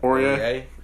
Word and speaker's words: Aurier: Aurier.